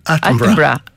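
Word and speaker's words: Attenborough: Από τον Ατένμπορο, ένα άρθρο για τον Ατένμπορο, ή πόσο το σωστά η προφορά. – Άτεμπρα.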